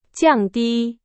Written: 降低

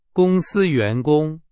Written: 员工

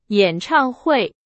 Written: 演唱会

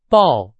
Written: 抱

